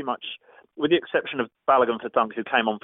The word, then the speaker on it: Balogun: They have Ryan as your goalkeeper, Bong, Balogun. Did I say that correctly? – Balogun, yeah. Balogun, yeah.